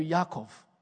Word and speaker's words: Yaakov: Excuse my pronunciation, but in Hebrew, it would be something closer to Yaakov.